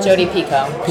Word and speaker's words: Jodi Picoult: Jodi Picoult.